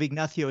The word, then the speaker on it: Ignacio: Four syllables in the first. And the abbreviation of Ignacio is Nacho, which doesn't make it any easier.